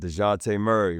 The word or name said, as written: Dejounte Murray